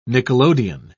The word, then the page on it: nickelodeon: British English